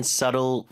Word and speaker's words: subtle: 자, clean and subtle flavor. 자, subtle의 B는 묵음입니다. Subtle 아니고 발음 다시요.